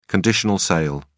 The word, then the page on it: conditional sale: British English